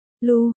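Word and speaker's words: Lugh: Anh sắp Lắc phát âm là Lu, là người anh hùng vùng Celtic hay còn gọi là thần mặt trời.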